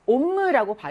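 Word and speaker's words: homme: 그래서 이것도요, 옴므라고 발음을 하죠.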